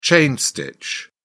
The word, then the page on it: chain stitch: British English